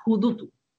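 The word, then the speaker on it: hudutu: Y ese es nuestro, se llama judutu.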